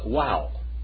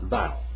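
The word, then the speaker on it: vav: Now I learned this next letter as wow, not vow. Okay, wow. Because wow is the Greek pronunciation of this word. Wow. Okay? But they now pronounce it, the Hebrews now pronounce this word as vow. That is this word right here. Okay? That one right there.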